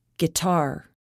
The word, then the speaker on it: guitar: The letter U is not pronounced after G and before a vowel. Guess, guidance, guitar, guest.